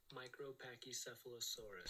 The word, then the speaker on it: Micropachycephalosaurus: Let's go to pronunciation. – Micropachycephalosaurus. – Pachy, I was right.